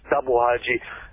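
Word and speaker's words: Tabung Haji: and it's spelled T-A-B-U-N-G, but pronounced Tabu Haji.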